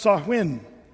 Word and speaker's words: Samhain: Well, if you want to look it up, it's pronounced Samhain. Samhain. But Samhain is spelled like this, S-A-M-H-A-I-N. It looks like Samhain. Samhain, but it's actually Samhain.